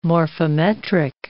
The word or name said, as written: morphometric